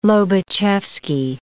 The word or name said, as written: Lobachevsky